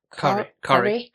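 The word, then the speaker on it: Kari: The opening scene, though, with them, with Ty and Corrie. – Even though it's spelled as Carrie.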